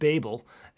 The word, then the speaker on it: Babel: Is it Babel or is it Babel? Because I hear both all the time. – It depends on which language you're speaking. – Haha, very funny. – We pronounce it Babel, and this word comes from the Hebrew word balal, which means to confuse.